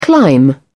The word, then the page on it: climb-down: British English